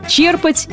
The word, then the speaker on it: черпать: Еще в начале 20 века правильным считалось ударение на последний слог – «черпать». Сегодня же словари единодушны. Правильно будет ставить ударение на первый слог – «черпать» и не иначе.